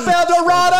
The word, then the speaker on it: El Dorado: And then the elevator, on the elevator up to the stage, it says El Dorado is pronounced like tornado. Because you get somebody out there, it's like, what's up, El Dorado?